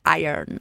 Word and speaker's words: Iron: mas aí em português fala Iron, mas em inglês é Iron.